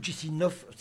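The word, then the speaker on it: Chișinău: 1 million d'habitants quand même, Chisinau, ou Chisinov, si on prononce, comment, non pas à la roumaine, mais à la russe.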